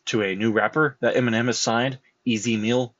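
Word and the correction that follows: EZ Mil: Easy Mill. I said that wrong. Easy, literally the letters Easy, and then Mill, M-I-L.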